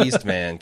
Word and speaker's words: Eastman: I think you said Easterman. – Easterman? – Yeah. – Oh, no, he's the Eastman.